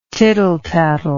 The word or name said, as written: tittletattle